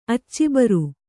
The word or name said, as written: ಅಚ್ಚಿಬರು